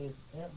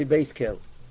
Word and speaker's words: Beis El: You might want to pronounce it Beis El, Beis El? – Yeah, because Beis El means literally the house of God. So some people don't like to pronounce it Beis El, so they say Beis Kel.